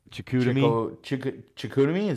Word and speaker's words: Chicoutimi: I'm gonna butcher this one. – Chicoutimi? – Is that how you? – Yeah, that's okay, that sounds right.